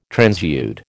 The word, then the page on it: transude: American English